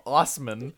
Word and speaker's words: Assman: Local news reports that Dave Assman, who pronounces his last name Osman, has been prohibited from putting his last name on his license plate, and he's determined to fight the ban.